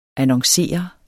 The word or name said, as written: annoncere